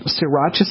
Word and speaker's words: sriracha: All right, let me spell it for you because it's spelled S-R-I, like Sri, like mm-hmm. Sri Lanka. – Okay. – S-R-I-R-A-C-H-A.